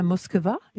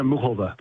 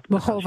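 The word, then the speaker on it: Muchová: but I can't help but bring up that Karolina Muskova? Is that how we say her name? – And she says Karolina Muhova. – Muhova. – She pronounces it.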